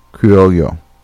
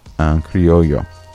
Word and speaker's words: Criollo: So keep in mind what it's called, Ají Criollo, and I'm probably pronouncing that right, incorrectly, but I spelled it C-R-I-O-L-L-O. The double L is more of like a Y sound, just in case anybody's interested. So, Ají Criollo.